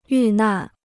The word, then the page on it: 遇难: yù nàn